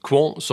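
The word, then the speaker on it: Qwant: Sen så har vi också Europaparlamentet, och det där var ganska kul för de har nyss bytt ut Google mot franska Quant. Jag var faktiskt tvungen att fråga äldsta sonen hur man skulle uttala det där, men det ska vara Quant.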